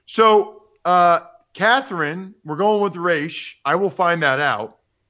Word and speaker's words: Raiche: I think it might be French, but I don't know how to pronounce it yet. It's R-A-I-C-H-E. Try to give it some French flavor.